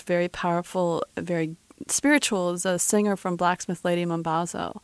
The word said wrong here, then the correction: Ladysmith Black Mambazo: or Ladysmith Black Mambazo. I always say that wrong.